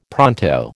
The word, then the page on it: pronto: American English